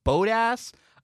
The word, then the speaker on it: Bottas: And, you know, on TV, they say Bottas. So I'm going to say Bottas. But I'm told that's wrong. People say it's Bodas, which is not a cuss word, I promise.